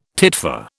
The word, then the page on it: titfer: British English